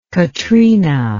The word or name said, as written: katrina